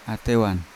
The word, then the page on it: atewan: Yawa